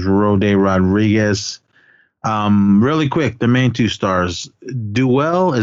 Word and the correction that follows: Dulé: is that how you say his name? – Dulé, yeah.